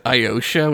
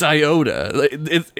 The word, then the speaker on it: Iota: They pronounce it Iosha, which drives me crazy. – Oh, yeah, it's Sigma Iosha. You're right, it is Iosha. – But it's Iota. It's written Iota.